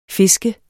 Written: fisker

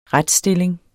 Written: retstilling

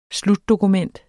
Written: slutdokument

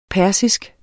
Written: persisk

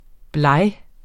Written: blege

